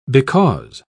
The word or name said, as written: because